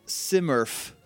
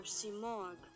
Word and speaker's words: Simurgh: Simurgh. S-I-M-U-R-G-H? Simurgh. – Simurgh or Simorgh.